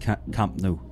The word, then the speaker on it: Camp Nou: It's actually pronounced Camp Nou.